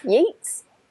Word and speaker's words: Yeats: How do you say his name? Yeats or Yeats? – Yeats. – Yeats. – Oh yeah, and Yeats.